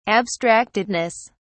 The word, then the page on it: abstractedness: British English